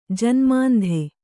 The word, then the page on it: ಜನ್ಮಾಂಧೆ: Kannada